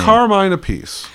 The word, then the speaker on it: Carmine Appice: Carmine Appice.